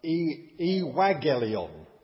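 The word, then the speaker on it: euangelion: It's not an N and a G, but anybody who learns Greek will discover fairly on in their lessons that in Greek, two G's together are pronounced as N-G. And hence, although it's a double G, it's pronounced evangelion, and hence, when transliterated into English, it becomes evangelical.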